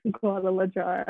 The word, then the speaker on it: Guadalajara: Guadalajara.